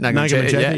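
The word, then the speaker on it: Naga Munchetty: Nagamon Chetty, yeah.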